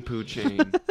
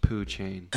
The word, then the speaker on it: Pouchain: It's Jean Poochain. P-O-U-C-H-A-I-N. That's pronounced Poochain.